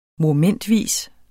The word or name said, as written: MOMENTVIS